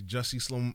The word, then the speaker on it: Jussie: I feel sorry for because now they on Instagram talking about she's Jolat Smollett. – She's Jussie Smollett.